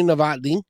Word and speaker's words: Novotny: Her name is Dr. Amy Novotny. I said that right, didn't I? – You did.